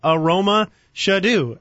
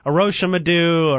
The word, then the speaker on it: Aromashodu: But I, I okay, it's Aromashodu seems like a hard name when you look at it, but it actually is pronounced phonetically. I mean, it is spelled as it is said, Aroma Shadu.